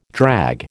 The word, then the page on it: drag: American English